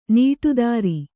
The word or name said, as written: ನಡುದಾರಿ